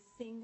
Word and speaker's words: Singh: My name is Sir Swati Singh. My first name is pronounced Sir Swati, and my last name is Singh as in song.